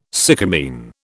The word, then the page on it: sycamine: British English